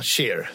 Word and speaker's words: Cher: Hur uttalar man det? Ja, Cher säger man.